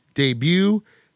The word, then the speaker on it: debut: What silent letter ends these words? Ballet, debut, depot. – T.